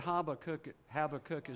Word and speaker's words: Habakkuk: Habakkuk? He said Habakkuk is okay.